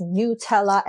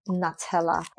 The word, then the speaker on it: Nutella: And yes, it is pronounced as Nutella and not Nutella.